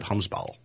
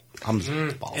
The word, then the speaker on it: Hvamsbal: I would just like to take a moment to give myself kudos for my pronunciation of Hvamsbal. Hvamsbal. – It's actually Hvamsbal. – Damn it. Really? – Oh, kudos.